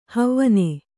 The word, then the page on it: ಹವಣ: Kannada